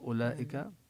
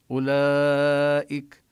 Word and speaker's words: أولئك: أولئك هنا الواو لا تمد وضعوا كما وضعوا على الألفات التي بآمنوا وعملوا التي هي بالرسم يعني فوق أولئك. هذه العلامة الدائرة الصغيرة للدلالة على أن هذا الحرف ليس له أثر في النطق يعني كأنه مرسوم لكن كأنه غير موجود.